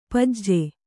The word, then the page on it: ಪಾಜೆ: Kannada